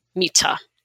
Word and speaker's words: Mita: You pronounce my name, Mita, and do it phonetically.